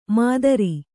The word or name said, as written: ಮಾತರಿ